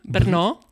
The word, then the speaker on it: Brno: De Brno Brune, Brno.